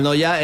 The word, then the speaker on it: Neuer: Manuel Noya era mi abuelo. Yo digo, ese pronuncia, es que es Neuer. Pero se pronuncia casi igual, sí, sí. – Sí, sí, se pronuncia en Noya.